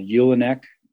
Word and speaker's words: julenek: We harvest that, and then we make these sheaves of wheat that are bundled together, and they're called Ulenek. It's spelled Julenek.